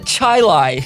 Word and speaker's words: Chili: It is spelled C-H-I-L-I, chili. It's the word chili, and they pronounce it Chilai.